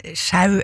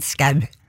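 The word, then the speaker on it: Schou: Og uansett, ja, altså, jeg tror virkelig ikke det er noen grunn til å uttale det øllet som hverken sjau eller show eller noe som helst annet enn skau.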